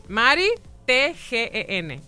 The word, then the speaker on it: Maritgen: Maritgen MD, estamos en Instagram, es Maritgen con I latina, T-G-E-N. – Mi nombre. T-G-N, es M-A-R-I, complicaron un poco. Mari. – Mari, T-G-E-N. – T de tomate, G-E-N.